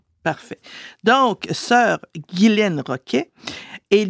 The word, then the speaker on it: Ghislaine: Oui, Ghislaine, parce que quand on regarde le nom Ghislaine, ça s'écrit G-H-I-S-L-A-I-N-E, et c'est un nom très ancien. Et dans les anciennes écritures, on sait qu'en français, il y avait beaucoup de lettres muettes. Et la lettre S devant le L était effectivement muette.